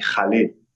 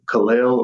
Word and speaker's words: Khalil: And I believe that from what I've heard, people who are fluent and speak Arabic call me Khalil. So 1,000%. – I don't speak Arabic, but from like hearing, you know, people, that's their native language, say it. That's how they pronounce it. But here in America, people say Khalil or Khalil, or some of my family calls me Khalil. It's just, I guess it depends on your accent, you know, but yeah.